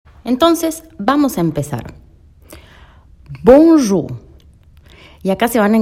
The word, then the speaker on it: bonjour: Bueno, la ON es nasal, por eso suena bon, sale como de la nariz, bon. Jour, la OU es una U.